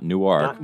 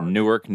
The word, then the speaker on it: Newark: Yes, Newark, not Newark, but Newark, New Jersey. – Spelled the same.